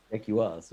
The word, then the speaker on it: Jaguars: Jaguars, that's right. – Jaguars. – And it's not like Austin Powers' Shaguar. That's how you got to remember to pronounce it.